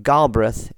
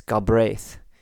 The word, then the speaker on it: Galbraith: It's Galbraith is not Galbraith, it's Galbraith. The emphasis on the second syllable.